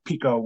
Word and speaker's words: Picot: But Sykes was British and Picot was French, as you may have guessed from those names. Especially if you realize that Pico ended with a T that you don't pronounce.